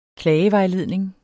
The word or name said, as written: klagevejledning